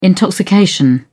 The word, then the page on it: intoxication: British English